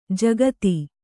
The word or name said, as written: ಜಕ್ಕಾತಿ